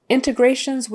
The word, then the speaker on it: integrations: And let's see, the last phrase I have here today is integrations with a third party provider. Integrations. The accent is on the next to the last syllable, which is often the case for long words, but not always, but often.